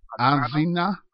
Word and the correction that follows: Azania: I think is how you pronounce his name. What was that? – Zolo Azania. – Azania. Zolo Azania, who is being held captive in the Indiana State Prison.